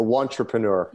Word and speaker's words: wantrepreneur: So you have a phrase, I understand, that you needed to decide to be an entrepreneur versus a wantapreneur. I'm not saying that right. How do you say it? – A wantrepreneur.